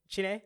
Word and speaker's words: Chiney: Chine? – Yeah, there you go.